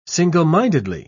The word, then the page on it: single-mindedly: British English